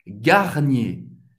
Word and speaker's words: Garnier: Garnier garnier. Duas sílabas, garnier, mais uma vez, e R no final de uma palavra vai ter um som do E, garnier.